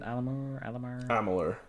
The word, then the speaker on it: Amalur: You will get Kingdom of Alamur. Is that how you say it? Amalur, I think. – Amalur. – Yeah. – I know it was one of those vowels. Vowel combinations.